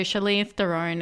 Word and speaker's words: Charlize Theron: So, Charlize Theron and Taron Egerton. Oh my gosh. I cannot pronounce anything live on air right now. – What are you saying? You got it right. – Hey? – You said it right.